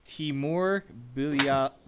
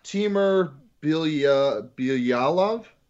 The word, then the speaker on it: Bilyalov: Oh, Timur Bilyalov. – Yeah, that sounds better than Bilyalov. – Here clearly, yeah, that's a little better than my attempt.